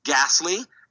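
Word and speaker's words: Gastly: The first one is called Ghastly, as in spelled like Gas and then Lee.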